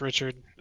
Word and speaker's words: Richard: It's Richard.